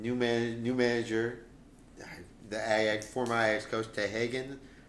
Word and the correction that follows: Ten Hag: Yeah, Ten, Ten Hag is the Dutch.